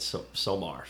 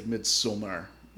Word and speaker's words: Midsommar: I got yelled at for pronouncing it Somar, so I went the other way this time. – Yeah, I think it's a little bit more nuanced. It's like Midsommar or something like that.